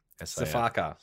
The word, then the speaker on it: sifaka: S-I-F-A-K-A. – Safaka.